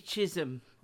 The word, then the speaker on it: Chisholm: First of all, I do think that the episode about Shirley Chisholm, I hope I'm pronouncing that correctly. – Chisholm.